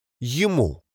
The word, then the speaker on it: Ему: Note that it rhymes with kamu and marku because it has the recipient marker. Yumu. It simply means to him.